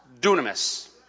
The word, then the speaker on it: dunamis: Now that word power in the Greek is spelled D-Y-N-A-M-I-S. Looks like dynamis. It's actually pronounced dunamis.